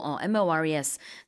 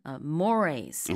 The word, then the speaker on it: mores: 단어라서 순간적으로 MORES 이렇게 발음을 했었는데 사전적으로 이제 발음 기호를 찾아보시면은 Morays Okay. – 이렇게 나와 있긴 합니다.